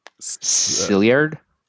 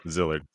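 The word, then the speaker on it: Szilard: Also, I'm looking at the name, the other name, and I think you should try pronouncing it. Sillard. That's it. We're going with that. Zillard? Nailed it. – Zillard. So Zillard. – 100% nailed it.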